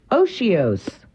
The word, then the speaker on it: otiose: The word is Oshios. – Oshios. – Oshios. – Oshios. – It's spelled, and this is where it gets a little confusing, because it's spelled O-T-I-O-S-E.